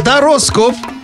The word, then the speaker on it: Тароскоп: будет жара Прямо с утра Ведь в Саймон Шоу все хорошо Будет жара Саймон Шоу на радио Энерджи Дорогие мои, слышали, как правильно произносится? Это вам не Таро Дратути. Тароскоп. Вот, так запомните.